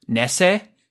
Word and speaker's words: Nese: Tony, I believe it's pronounced Nese. It's Tony Nese.